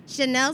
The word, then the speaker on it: Shanelle: Shanelle Slaughter, S-H-A-N-E-L-L-E. Last name Slaughter, S-L-A-U-G-H-T-E-R.